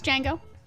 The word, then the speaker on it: Django: And she'd be like, it's Django. – Oh, the D is silent.